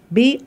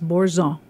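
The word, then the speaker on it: Boursin: Bourzin, Bourzin, if you say it the way Americans say it, but it's French and it's Bourzon.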